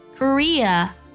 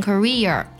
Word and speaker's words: Korea: 那么类似呢，还有这几个国家容易读错，特别容易给后面加儿化音，像 Australia 不要读成 Australia， Malaysia 不要读成 Malaysia， Korea 不要读成 Korea。